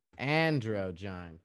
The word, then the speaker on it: androgyne: It sounds kind of like it's almost comparable to an antigyne. Antigyne, is that right? Did I say that correctly? – Androgyne.